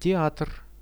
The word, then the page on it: teatr: Russian